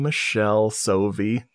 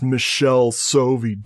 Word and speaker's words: Michele Soavi: And they're like, they commented like, it's pronounced Michelle Sovi, dude.